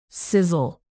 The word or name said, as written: sizzle